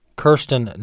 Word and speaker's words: Kirstjen: Kirstjen Nielsen, I believe is how you pronounce her name.